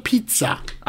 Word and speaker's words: pizza: Daha doğrusu pizza. – Aynen pizza. 2 Z e, İtalyanca'da TZ diye okunur.